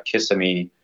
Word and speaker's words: Kissimmee: So, you know, when you're going to locations, like another location that I'm thinking of is Kissimmee. Hopefully I'm saying that right. – Yeah. No, you are. My wife's from Florida. So yes, this is Florida. This is the right way to say it.